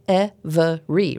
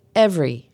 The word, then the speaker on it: every: The word every looks like it should have three syllables, every, right? But it's pronounced with two, every, as in every guy.